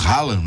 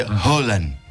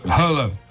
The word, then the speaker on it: Haaland: Nós falamos Haaland, né? Mas é Haaland. – Haaland.